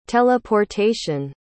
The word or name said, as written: teleportation